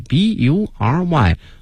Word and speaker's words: bury: 用这个 bury b u r y。同学们注意啊，这个词它发音 b u 这个 u 呢要发成小的这个 r 音 bury，因为受到这个 like 的影响呢，这个 bury 后面加上了 ing。